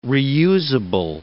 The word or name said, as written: reusable